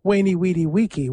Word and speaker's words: Veni, Vidi, Vici: Well, if you pronounce it with what we think was the classical pronunciation, it's Wainy Weedy Weeky, which sounds a little bit like a summer camp. Camp Wainy Weedy Weeky. No, Veni Vidi Vici. So I would go with the Italian pronunciation.